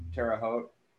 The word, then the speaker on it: Terre Haute: He decided to remain a fireman on the run between Terre Haute. I don't know if I'm saying that right or not. Terre Haute.